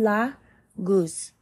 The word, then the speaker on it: Laguz: Phonetic value is L. Pronunciation la goose.